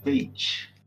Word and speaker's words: Veit: Esse é o, é o jeito certo de pronunciar o seu nome? Sim. Ok, Veit.